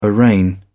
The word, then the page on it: arraign: British English